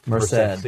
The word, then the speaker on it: Merced: Merced.